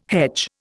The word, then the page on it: hedge: Indian